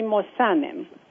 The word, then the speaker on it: Mossanen: Did I just slaughter your last name? How do you actually say it? I think I didn't say it correctly. – Dora Levy Mossanen. – Mossanen. Okay, I put the accent on the wrong syllable.